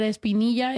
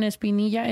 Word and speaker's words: espinilla: Oh, yeah, I just found out that espinilla is shin and espinilla is pimple. – How do you say pimple? – Espinilla.